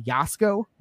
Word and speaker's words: Jasko: But in terms of investigators, I watch, his name is pronounced Jasko, but it's J-A-S-K-O. And also another channel is CJ Faison, F-A-I-S-O-N.